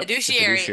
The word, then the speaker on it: fiduciary: Fiduciary? How do you say that? – Fiduciary, yep. – Fiduciary to your client always.